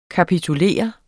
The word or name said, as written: kapitulere